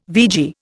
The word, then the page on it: vegie: Indian